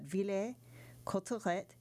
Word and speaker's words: Villers-Cotterêts: In 1802, Alexandre Dumas was born in Villers-Cotterêts, and forgive my French pronunciation, but Villers-Cotterêts, the third child of Thomas Alexandre Dumas.